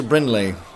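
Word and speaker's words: Bringley: Why don't you sit up front, next to Brinley, with the silent G. – Brinley.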